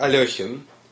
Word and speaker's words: Alekhin: I pronounce it Alekhin, but over the years, I've gotten used to many versions of my surname, and I've accepted them all as, you know, as the right one.